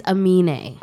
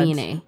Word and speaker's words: Amine: I haven't really listened to Amine, who is... Am I saying it right? – It's Amine. – Oh, God. – Amine.